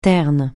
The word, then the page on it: terne: francais